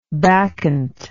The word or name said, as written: bacchantic